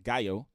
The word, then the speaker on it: Gallo: And, you know, Gallo in Spanish is actually pronounced Gallo.